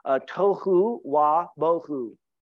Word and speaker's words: tohu wa bohu: Actually, without form and void is pronounced in Hebrew, and I do it the best I can, tohu wa bohu. It is a rhythmic expression.